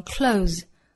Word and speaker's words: clothes: 那很多本族人呢会简化发音，只发一个末尾的。 z 的发音，把前面的这个 th 对应的 v 的发音给略掉，那直接读成 close, close, select your clothes every day, select your clothes every day.